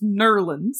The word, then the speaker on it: New Orleans: They said, no, it's pronounced Nerlens.